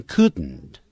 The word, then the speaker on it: couldn't: 前面用了助动词 couldn't, 记住他的发音，是 can't 或者是 cannot 的过去时，念的时候念成 couldn't。你看，如果念慢的时候会念成 couldn't，但是在美式英语里头，我们通常用鼻音出来发这个 den 的时候，用鼻音出来叫 couldn't。